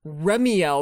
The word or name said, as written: Remiel